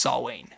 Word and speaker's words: Samhain: Also, a couple of weeks ago, or a couple of days ago, I found out that it's pronounced Samhain, not Samhain.